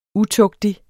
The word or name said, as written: UTUGTIGE